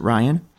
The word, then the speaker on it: RYAN: Is that how you pronounce it, Ryan? – Yes, generally in English, people do call it Ryan. The Russian pronunciation, since it is a Russian acronym, is a bit different. But yes, Ryan.